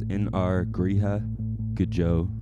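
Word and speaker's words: In ár gCroíthe go deo: So the song, the way you pronounce it is In Ar Gríha Gjo.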